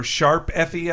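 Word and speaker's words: Tokyo Mirage Sessions ♯FE: It is Note. It is Musical Note FE, or Sharp FE, I believe it is.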